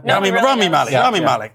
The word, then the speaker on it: Malek: Rami Malek.